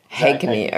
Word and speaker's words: Haigney: Haigney.